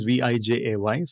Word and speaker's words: Vijay: I think the worst part is in Dutch IJ is vowel. No, my name is Vijay, so it's Vijay. Yeah. But IJ is I in Dutch.